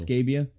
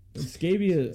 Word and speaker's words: Scabbia: He was uh, in a highly publicized relationship with Cristina Scabbia, lead singer of Italian. – Her last Battle. name is Scabbia. Yep. Like the skin infection.